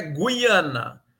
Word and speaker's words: Guiana: Mas eu sempre falei Guiana. Qual é o certo, professor? – Olha, o certo é Guiana